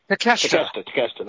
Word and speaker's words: Takeshita: Takeshita.